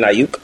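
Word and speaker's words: Aiyuk: That Ayuk, I don't know how you say his name. – Brandon Ayuk.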